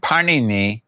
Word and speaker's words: Panini: And Panini, eh, kind of like the, I mean, his name is very much like the uh, Italian bread, Panini. It's pronounced slightly different.